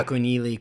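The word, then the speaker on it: Akunyili: Nejideka, yes. Akunili, Crosby, yeah, I think I pronounced it right. – Yeah, no, I think you did.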